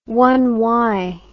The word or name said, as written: one-worlder